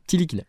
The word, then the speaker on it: Ntilikina: Côté arrivé, Frank Ntilikina. – Tilikina. – Non, Tilikina. – Tilikina. – Franck. Tu prononces pas le N.